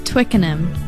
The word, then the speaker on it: Twickenham: Pronounce it now. – It's Twickenham. – Twickenham? – Yeah. – Did I get it right? – Yeah.